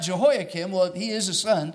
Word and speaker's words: Jehoiakim: All right, the first is Jehoiakim. Jehoiakim, if you are taking notes, let me spell it for you. It's J-E-H-O-I-A-K-I-M, Jehoiakim.